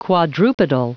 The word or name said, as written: quadrupedal